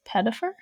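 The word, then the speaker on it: Pettyfer: He's Alex Pettifer. Is that how you say his name? – Yeah.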